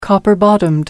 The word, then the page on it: copper bottomed: American English